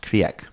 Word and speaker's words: Kriek: Crake IPA, I think. It was called like Viking Scream or something rad like that. – My understanding is it's pronounced Kriek.